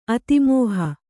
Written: ಅತಿಮೋಹ

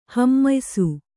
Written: ಹಮ್ಮಯಿಸು

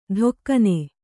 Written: ಧೊಕ್ಕನೆ

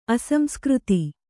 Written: ಅಸಂಸ್ಕೃತ

